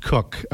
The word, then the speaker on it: Coch: He's on Twitter, and it's spelled C-O-C-H. It's pronounced Cook.